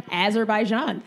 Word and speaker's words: Azerbaijan: Can you say it one more time so I can say it properly? – Azerbaijan.